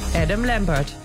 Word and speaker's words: Adam Lambert: Du hast Adam Lambert. – Bird. – Ich glaube, ich habe ihn falsch ausgesprochen. – Es ist sehr, sehr früh am Morgen. – Nein, ich wollte sagen, du hast ihn richtig, du hast ihn perfekt ausgesprochen.